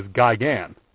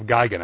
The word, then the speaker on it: Gigan: But, you know, on the same token, the monster Gigan, you'll notice how I pronounce that. In the United States, he's generally known as Gigan. And I like the Japanese pronunciation of Gigan. I like the more clipped sound.